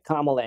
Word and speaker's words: Kamala: Like, or even people act like Kamala Harris is such a hard name to pronounce. – Yeah, apparently. – Right. You just, you don't want to put the stress on the first syllable? – Yeah.